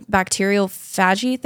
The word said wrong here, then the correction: bacteriophage: Bacteriophage therapy, which a lot of people mention.